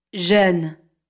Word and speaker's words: Jeune: This consonant is pronounced like the Z in the English word azure. – Jeune.